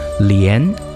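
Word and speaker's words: lian (莲): In Chinese, the lotus has two different names, respectively pronounced as lian and he.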